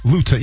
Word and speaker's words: Luton: Yeah, rhymes with gluten, not crouton. – Exactly. Or futon, if you will. Luton.